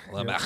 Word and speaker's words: Lamech: Well, down through the line of Cain, earlier in verses, those verses where it goes through his Cain, we get this character, Lamech. I think I'm saying that right. – Lamech. – Lamech. Yep. I don't know.